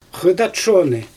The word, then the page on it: xɯjdat shonɯ: Xyzyl